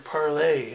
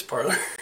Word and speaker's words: Parler: Yeah, the funny thing about that is they kept trying to say it's pronounced like the French word parlay, and I'm like, no, it's parlor.